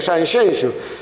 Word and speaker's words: Sanxenxo: Cando isto ocorra, cando os galegos aprendan que as xotas de Irixo e de Bixueses e os xes de Punxín e de Sanxenxo se pronuncian como as CH francesas, teremos dado un grande paso na verdadeira pronunciación.